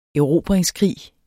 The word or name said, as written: erobringskrig